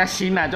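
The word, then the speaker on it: Xena: kok Sena sih Sin se, eh, tulisan Sena eh, iya ngomongnya Sina cok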